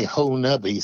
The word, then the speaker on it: Honobia: Of course, Hanobi is spelled Honobia, and they say Ho-Nubby, so I'm probably not pronouncing this correctly.